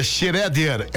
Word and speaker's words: Schroeder: Se pronuncia Schroeder, é o município aqui perto de Jaraguá do Sul.